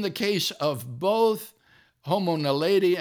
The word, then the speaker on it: naledi: These include Homo naledi." Now, am I saying that right, Bill? – Yes, yes.